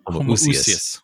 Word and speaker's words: Homoousios: Homoousios.